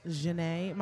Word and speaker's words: Jene: J-E-N-E. Janae. My mom. – J-E-N-E? – Yes.